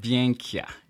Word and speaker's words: Byenkya: Byenka.